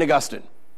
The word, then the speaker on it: Augustine: If you had Martin Luther or St. Augustine. By the way, it's pronounced Augustine, not Augustine, but, you know, it's one of my pet peeves. It's Augustine. Jesus still loves you if you say Augustine.